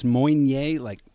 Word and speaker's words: Meunier: It's spelled Meunier, and it's pronounced Meunier, you know, you can phonetically spell it and say, hey, my buddy told me to look for some of this.